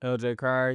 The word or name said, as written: LJ Cryer